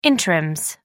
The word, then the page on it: interims: American English